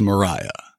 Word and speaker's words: Maria: Lydia Mariah Francis always hated the name Lydia, so she went by her middle name, which was spelled Maria, but she pronounced it as Mariah.